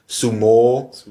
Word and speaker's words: Sumo: Nun, ich bin derjenige, der Sumo gut findet, und das wäre jetzt sozusagen die exakte Aussprache, wäre langes O hinten, Sumo.